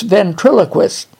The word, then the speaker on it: ventriloquist: You didn't pronounce that word right. It's ventriloquist. Yeah, that's what I said, ventriloquist.